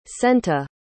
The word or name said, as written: Senta